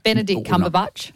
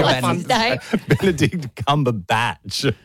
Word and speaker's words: Benedict Cumberbatch: Benedict Cumberbatch. – Benedict Cumberbatch. – You didn't even say it right. – Antonio Benedict Cumberbatch. – Benedict Cumberbatch. – Yeah, there you go.